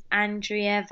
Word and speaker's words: Andrea: So uh, my Instagram is Andrea Vasiliou,